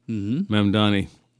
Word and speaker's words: Mamdani: Another thing that's happened recently, there's a lot of talk about the new guy for the mayor of New York, Mamdani. Mm-hmm. And I have to think that through because it seems like the M and the N should be reversed.